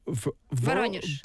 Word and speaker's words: Voronezh: your hometown name, I might say this wrong, but Voronezh. – Voronezh. – Yeah. – Voronezh.